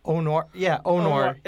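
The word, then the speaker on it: O.Noir: It's called the Anwar. – Okay. – It's Dine in the Dark. They bring you in this dark room. Did I pronounce that right? – Onwar. – It's Onwar.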